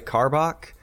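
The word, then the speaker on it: Karbach: I'm doing a Carbach. I think it's. I think I'm pronouncing that right. Carbach. – Yeah.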